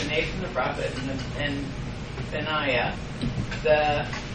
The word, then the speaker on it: Benaiah: Benaiah. – Benaiah. Okay, Benaiah. You're doing the emphasis on the Nai.